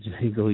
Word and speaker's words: Django: He said, Django, he said, in the D is silent.